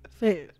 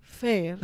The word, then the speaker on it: Fair: Soy Fair, ¿ok? Eh, si van a o sea, si van a hacer mi nombre, por favor, pronuncienlo como es. – ¿Cómo es? – Fair.